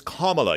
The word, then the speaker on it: Kamala: So it's Kamala. You almost think of it like a comma in a sentence or an O sound, Kamala.